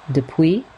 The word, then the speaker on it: du Puy: They are often sold as lentils de puy. I believe that's how it's pronounced. D-U-P-U-Y.